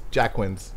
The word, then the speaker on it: Jacquin's: Jacquin's, Jacquin's. We've been calling it everything. – Joaquin's, Joaquin's to Jacquin's. Jacquin's. – Like, like, nah, it's Jacquin's.